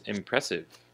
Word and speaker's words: impressive: And our next word is impressive. Impressive, with the emphasis on the second syllable, pre, impressive.